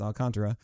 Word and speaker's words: Alcantara: I had a lot of conversations about how to pronounce his name, but it's Alcantara.